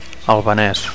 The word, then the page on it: albanes: Catalan